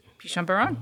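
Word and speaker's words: Pichon Baron: So they brought a 95 Chateau Pinchon Baron. Did I say it right? – Pinchon Baron, yeah.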